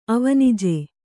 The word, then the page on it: ಅವನಿಜ: avanija